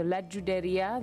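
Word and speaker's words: Judería: In her second album, La Juderia, and in Ladino you actually pronounce the J as a J, unlike in Spanish, which would be a Ch. So La Juderia, the Jewish Quarter.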